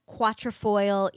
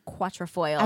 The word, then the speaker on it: quatrefoil: Got me tortoiseshell quatrefoil earrings. – Is that how you pronounce that? – Yeah, that's what that shape is, a quatrefoil.